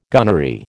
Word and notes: gunnery: American English

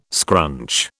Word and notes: scrunch: British English

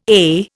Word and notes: ay: Indian

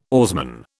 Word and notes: oarsman: British English

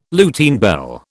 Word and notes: Lutine bell: British English

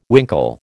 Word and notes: winkle: American English

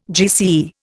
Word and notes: GCE: Indian

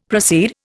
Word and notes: proceed: Indian

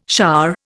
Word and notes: shah: Indian

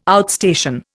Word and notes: outstation: Indian

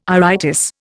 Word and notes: iritis: Indian

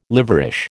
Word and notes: liverish: American English

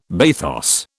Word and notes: bathos: British English

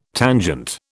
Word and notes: tangent: British English

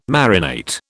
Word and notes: marinate: British English

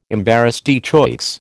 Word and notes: embarras de choix: American English

